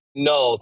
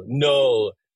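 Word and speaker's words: Knull: Null. Silent K. K-N-U-L-L. Null.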